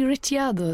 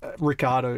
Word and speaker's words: Ricciardo: Well, why don't you say Ricciardo then instead of Riccardo? – Well, yeah, we grew up in Australia, and Riccardo is the more, I would say, Australian pronunciation.